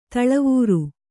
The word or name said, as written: ಧಾಳಿವರಿ